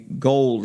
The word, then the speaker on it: Gould: They were the away team, and they won by a field goal kick by Robbie Gould, is how it's pronounced. It's spelled Gould, but Gold, I understand.